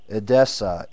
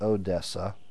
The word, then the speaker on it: Edessa: Early tradition, as believed in the 4th century, represents him as preaching in Parthia or Persia, and as finally being buried in Edessa, Edessa. That's not Odessa. No, he wasn't buried in Texas. That's spelled Edessa. Edessa.